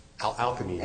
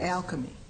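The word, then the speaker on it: Alkermes: That is to say, if the drug that you're relying on, active ingredient or not, was essential to the approval, I'm assuming Arkema, is that the way it's pronounced? – Alchemy. – Alchemy, okay.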